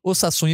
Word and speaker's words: Osasuna: O Campeonato Espanhol tem apenas dois jogos únicos, que é Real Madrid, Getafe, Osasuna e Barcelona.